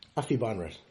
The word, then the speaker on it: Aphibarnrat: Kiradech Aphibarnrat. – Aphibarnrat.